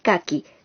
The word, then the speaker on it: kaki: Entre todos ellos, hay uno en particular del que queremos hablarles hoy, el kaki, cuyo nombre proviene del japonés, ya que se pronuncia igual.